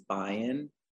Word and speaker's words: Bion: The pronunciation of my name is Bion, like Zion.